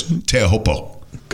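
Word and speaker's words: Teahupo (Teahupoʻo): You were trying to say it like they say it, like because Drew's white. World travels, so he's trying to say it like the natives would say it, which is Teahupo.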